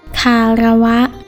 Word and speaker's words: คารวะ: สวัสดีค่ะพบกับรายการคำวัดวันนี้เสนอคำว่าคาระวะ